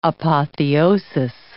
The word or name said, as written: apotheosis